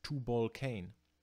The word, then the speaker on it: Tubal-Cain: Die haben sich gedacht, wenn man das Wort Tubal-Cain Englisch ausspricht, dann klingt es anders, dann ist es Tubal-Cain, auf Englisch ausgesprochen. Und Tubal-Cain, der hebräische Tubal-Cain, Tubal-Cain, klingt wie auf Englisch Two Balls, One Cane.